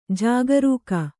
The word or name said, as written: ಜಾಗರಿಗ